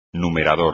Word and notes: numerador: espagnol

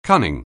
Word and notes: cunning: anglais

